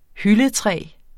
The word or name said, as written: hyldetræ